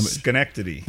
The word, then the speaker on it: Schenectady: Schenectady. – There we go. Schenectady.